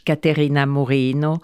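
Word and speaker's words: Caterina Murino: Alors, à ma gauche, Caterina Morino.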